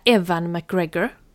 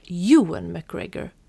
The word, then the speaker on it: Ewan McGregor: Jag vill säga Evan McGregor. Men jag tror inte det uttalas så. Och så tänker jag... Hur uttalas det annars? Uttalas det Ewan McGregor? – Jag tror det, ja.